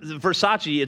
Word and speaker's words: Versace: If you've never heard Versace, it's pronounced Versace.